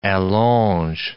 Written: allonge

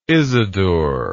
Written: isadore

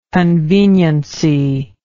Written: conveniency

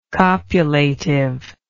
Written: copulative

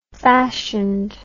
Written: fashioned

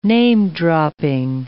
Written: name-dropping

